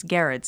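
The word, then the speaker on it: Gerads: I think I've always said Mitch Gerards. But now I know it's Garrett.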